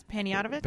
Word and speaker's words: Panayotovich: We got some awesome stuff coming up from Sam Panayotovich. Did I say it right? I always say it as quick as I can. – I think that's Panayotovich.